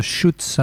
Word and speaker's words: Chutes: Après This Is Lorelai, vous avez pu écouter de Schutz, un groupe de San Francisco. Alors Schutz, ça s'écrit comme chute en français, C-H-U-T-E-S.